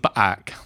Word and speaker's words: Paak: Do you think it might be two syllables? Paak. – For us, from now on, it's Anderson Paak.